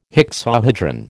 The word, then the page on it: hexahedron: American English